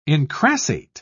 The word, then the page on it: incrassate: British English